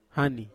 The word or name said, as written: hani